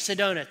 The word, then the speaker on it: Sedona: I think it's Sedona. Is that right? Did I say that right? S-E-D-O-N-E. Is that how you say that? Where the Grand Canyon is? Tell me. Yes. Thank you. Sedona.